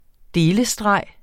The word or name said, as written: delestreg